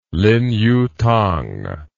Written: lin yutang

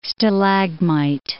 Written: stalagmite

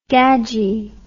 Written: gadgie

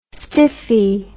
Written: stiffie

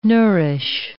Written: nourish

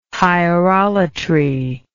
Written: hierolatry

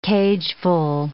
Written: cageful

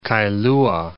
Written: Kailua

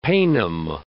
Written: paynim

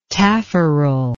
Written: taffarel

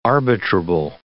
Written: arbitrable

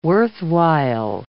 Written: worthwhile

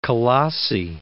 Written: Colossae

